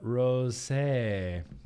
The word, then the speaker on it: rosé: Firestone Walker Bretta Rosé. Let's see. Bretta Rose. There's an accent on there. So I'm pronouncing it. I'm pronouncing it as a rosé. A rosé.